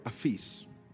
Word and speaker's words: aphes: Forgive is the Greek word aphes. A-P-H-E-S. P-H in Greek, we pronounce it like an F.